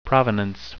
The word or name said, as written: provenance